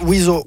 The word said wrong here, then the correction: Oizo: Jeg kan se her, der står pronunciation. – Ja. – Wazo. – Det er Wazo. – Der står på wiki her. – Okay. – Wazo. Fransk selvfølgelig.